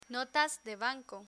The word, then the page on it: notas de banco: spanish